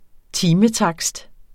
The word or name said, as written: timetakst